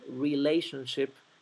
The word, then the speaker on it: relationship: El conjunto de letras tion se pronuncia shun, shun. Relationship.